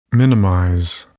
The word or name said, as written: minimize